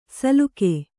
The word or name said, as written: ಸಲುಕೆ